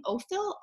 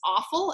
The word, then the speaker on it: Offill: Weather by Jenny, I believe you say her name Ofill, O-F-F-I-L-L. At least I would, because I think the other pronunciation is awful, and who wants their last name pronounced awful?